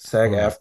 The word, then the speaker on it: SAG-AFTRA: Did I say that right? SAG-NAFTA? Is there an R in there? – I think it's AFRA. Wait. – SAG-AFTRA. Hold on.